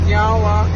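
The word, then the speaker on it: Niá:wen: So, in our language, we say nyawa, which means thank you.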